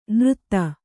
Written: ನೃತ್ತ

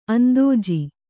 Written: ಅಂದೋಜಿ

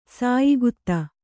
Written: ಸಾಯಿಗುತ್ತ

